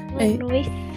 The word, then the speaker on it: Illinois: Am I saying Illinois normal? – Yeah. – Every time I read Illinois... – Illinois? – Yeah, I feel like I'm saying it wrong. – No, I think you're saying it right.